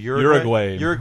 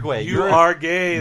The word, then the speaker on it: Uruguay: How do you say the name of the country? – Uruguay. – You are gay. That's how Homer pronounces it.